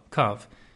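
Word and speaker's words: Kav (קוה): So it refers to the same Kav, circumference, but it uses, there's a creek sieve, and actually the word Kav is written is Kuf Vav He, even though it's pronounced Kuf Vav, Kav.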